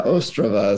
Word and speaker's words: Ostrava: Yeah, Ostrava is the way they pronounce it.